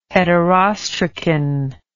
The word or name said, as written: heterostracan